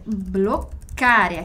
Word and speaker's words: bloccare: Sai come si fa per bloccare? Aqui também, bloccare, doppia C, quindi pronuncia delle doppie. Bloccare, sempre aquela pausa, aquela demoradinha, vero?